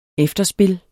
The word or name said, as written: efterspil